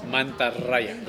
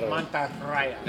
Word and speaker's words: Mantarraya: Yes, Mantarraya. – Mantarraya. – You have to roll your R's. – Mantarraya.